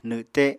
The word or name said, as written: nwte